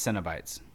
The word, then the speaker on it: Cenobites: I think it's Cenobites. – Okay. – Yeah, it's like Cinnabon.